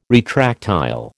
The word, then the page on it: retractile: American English